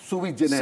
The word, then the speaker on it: sui generis: Jury generis? – Yes. – How do you say that again, sir? – Sui generis. – Sui generis, I'm sorry.